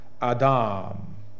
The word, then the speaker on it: Adam: Adam, which in the Hebrew, and if you want to sound like you know what you're talking about when you say Hebrew, you pronounce it Adam.